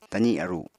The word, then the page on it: tazi aru: apatani